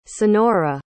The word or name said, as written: sonora